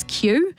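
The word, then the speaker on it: queue: he says the word for him is Q. It looks like it should be spelled wrong because it has four silent letters after the Q. But Q, Q-U-E-U-E, is the correct spelling.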